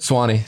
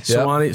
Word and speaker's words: Suwanee: You're from, was it Suwanee, right? – Swanee. – Swanee. Yep. Sorry for the mispronunciation.